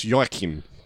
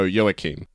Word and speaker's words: Joachim: So they mispronounce it as Joachim, sort of like Joaquin Phoenix. – Uh-huh. – But he gets upset and he's like, no, it's Joachim.